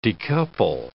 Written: decouple

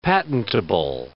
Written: patentable

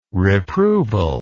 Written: reproval

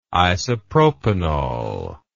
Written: isopropanol

